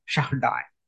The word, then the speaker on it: Shaddai: Also, the Hebrew name, whatever we read in 17:1 Genesis, so the Hebrew name used there is El Shaddai, that it will be pronounced as Shaddai.